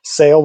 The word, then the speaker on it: Saol: And it's the most mispronounced, name in pharmaceuticals. It's SAOL, SAIL, which is Gaelic for life, which is a pretty cool name.